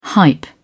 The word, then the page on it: hype: British English